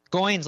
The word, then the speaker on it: Goins: Goins like coins.